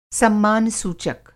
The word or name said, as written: सम्मान सूचक